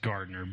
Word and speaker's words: Gardiner: I'm sure it's pronounced Gardner, but yeah, there's an I in there between the D. – Okay. – So it looks like Gardiner, like a place you would go to eat alligator gar.